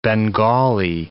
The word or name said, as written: Bengali